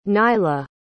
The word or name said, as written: Nylah